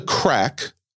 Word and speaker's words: craic: He says, "What's the crack I Googled this. It's spelled C R A I C.